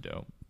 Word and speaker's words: Do: but through Doe, which is his name, it's spelled D-D-O. Mm-hmm. And I thought it was Do for the longest time, but it's called, it's pronounced Doe.